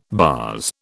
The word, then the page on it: baas: British English